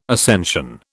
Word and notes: ascension: British English

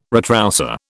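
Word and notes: retroussé: British English

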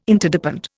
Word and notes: Interdepend: Indian

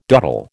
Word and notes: dottle: American English

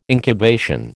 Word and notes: incubation: American English

